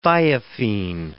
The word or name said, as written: thiophene